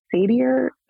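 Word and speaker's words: Satter: Ellen Sadier is how you say her last name.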